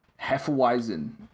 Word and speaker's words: Hefeweizen: It is spelled H E F E W E I Z E N. Hefeweizen is the closest. Hefeweizen, yeah. Sorry? Uh, it's a Hefeweizen. – Oh, Hefeweizen. German, okay.